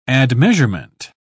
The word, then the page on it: admeasurement: British English